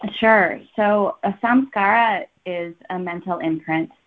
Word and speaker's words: samskara: Samskara? – Yes, and, samskara.